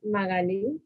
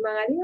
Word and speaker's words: Magali: Mm, Magali. La gente me dice, ¿y Magali o Magali? O Magali. Yo, bueno, no sé, lo que más te guste.